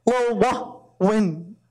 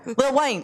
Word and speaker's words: Lil Wayne: Lil Wayne. Wayne. Lil Wayne. Say it again. – Lil Wayne.